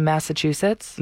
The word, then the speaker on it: Massachusetts: Have you heard about the woman in Massachusetts? – No, but congrats on saying it properly this time. Usually you say massive too.